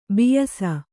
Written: ಬೇಯಿಸು

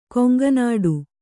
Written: ಕೊಂಕುನೋಟ

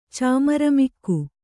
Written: ಚಾಮರಮಿಕ್ಕು

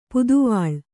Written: ಪುದುವಾಳ್

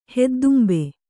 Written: ಹೆದ್ದುಂಬೆ

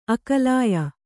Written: ಅಕಲಾಯ

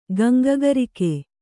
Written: ಗಂಗಗರಿಕೆ

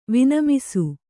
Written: ವಿನಮಿಸು

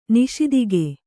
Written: ನಿಸ್ತೂಕ

